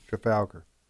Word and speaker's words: Trafalgar: There's a new poll out today from Trafalgar. How do you pronounce that? – Trafalgar.